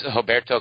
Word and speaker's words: Roberto: You actually pronounced it with, is it Roberto? Like, is it kind of a softer R? Pronounce your name for me again. – Yeah, it's very difficult for Americans to pronounce the way we do in Brazil. So it's actually Roberto. – Is Roberto close enough if I say it like that? – Yeah, it's perfect.